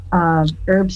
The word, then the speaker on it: herb: So where does herb come from, as opposed to the real way of saying it in English, herb? – Well, that is a wonderful question. I think the British Herb Society, the British say herb with the H sound.